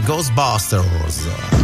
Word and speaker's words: Ghostbusters: Con la pronuncia inglese Ghostbusters.